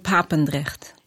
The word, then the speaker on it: Papendrecht: So you're from a small town in the Netherlands, which I tried very hard to pronounce. I'm going to ask you to pronounce it for me. It's called Papendrecht.